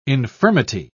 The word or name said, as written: infirmity